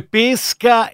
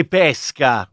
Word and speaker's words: pesca: Certo, come pesca e pesca, no? – Esatto. – Perché c'è quello che viene da piscis e quello che viene da persica. Esatto. E quindi l'accento è diverso, secondo che si tratti della pesca frutto oppure della pesca.